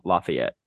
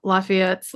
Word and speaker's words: Lafayette: Lafayette's like Lafayette. No, they say, how do they say it? Lafayette. Elliot will say it. No, they don't say it like we say it in Indiana.